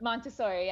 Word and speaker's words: Montessori: So you've done what's it, Montessori? Is that what you pronounce? How you pronounce that? – Montessori, yes.